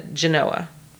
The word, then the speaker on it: Genoa: He crossed over into Italy at Brenner and made his way to Genoa. And I hope I pronounced that correctly.